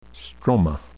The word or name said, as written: stroma